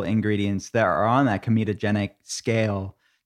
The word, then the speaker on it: comedogenic: Did I say that right? Comedogenic? – Yeah. – It sounds in my mind, every time I'm saying that, I'm thinking of comedic. But it's not funny. It's not funny at all.